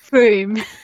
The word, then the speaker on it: Frome: But it's Frome, right? I've got it. – It's Frome. I had this conversation with an American friend last night. He was like, you really need to tell people how it's pronounced.